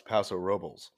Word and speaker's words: Paso Robles: Yes, but it's actually pronounced Paso Robles. – Paso Robles. Everyone says it that way. but it's Paso Robles.